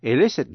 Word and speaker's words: elicit: Vậy tóm lại là hai chữ illicit, get information from someone, đánh vần E-L-I-C-I-T, và illicit, I-L-L-I-C-I-T, phát âm giống nhau, nhưng mà illicit là động từ.